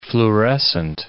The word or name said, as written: fluorescent